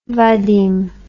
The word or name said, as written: vadim